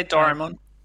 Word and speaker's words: Doraemon: Yeah, Doraemon.